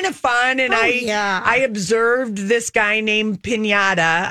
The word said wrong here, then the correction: Pineda: I don't know if I'm saying his name right, the pitcher. – Pineda.